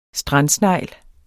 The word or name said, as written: STRANDSNEGL